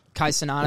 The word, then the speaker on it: Kai Cenat: Kai Cenat.